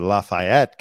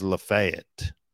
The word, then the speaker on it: Lafayette: It's you know, like the Frenchman Lafayette. I was pronouncing it Lafayette County. And the radio guy who I was working for pulled me aside and said, it's pronounced Lafayette.